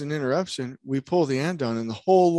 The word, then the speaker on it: andon: And I don't, real quick, Felipe, do they call andon or ondon? What's the pronunciation? I always in English in the United States, it's andon.